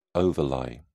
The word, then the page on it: overlie: British English